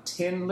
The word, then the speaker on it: Thin: The band's name is a play on Tin Lizzy, Thin being pronounced tin in an Irish accent.